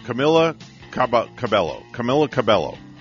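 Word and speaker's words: Camila Cabello: Camilla Cabello. Hope I say that right. – That would be correct, yes.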